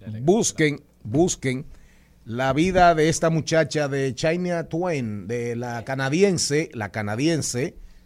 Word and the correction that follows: Shania Twain: Shania Twain. – ¿Cómo se pronuncia? – Shania Twain. Shania ¿cómo? Repítamelo. Shania Twain.